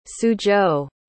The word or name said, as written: suzhou